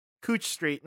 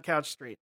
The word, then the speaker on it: Couch Street: In Portland, all we ever talked about was the Brain Smasher and how it's pronounced Cooch Street, not Couch Street.